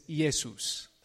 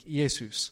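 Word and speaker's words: Iesus (Ἰησοῦς): And in Greek, Jesus' name is Iesus. That's how you say it in Greek, Iesus.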